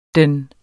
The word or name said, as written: den.